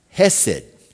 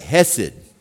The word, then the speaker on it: hesed: So the word for loving kindness, I think it's up here, it's pronounced hesed. Let's try to, everyone say that, hesed. It rhymes with blessed. That's a good way to remember it. Blessed, hesed.